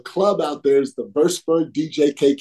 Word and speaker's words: Würzburg: And for people who don't know, Würzburg, pronounced properly, the home of Dirk Nowitzki, the home of Maxi Kleber.